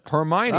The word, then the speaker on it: Hermione: Following tropical storm Hermione. – Hermione. – Hermione. – Is that how you pronounce it? – Hermione.